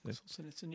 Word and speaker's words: Solzhenitsyn: Solzhenitsyn, I can't say his name. – Solzhenitsyn, yeah, yeah.